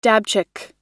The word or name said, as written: dabchick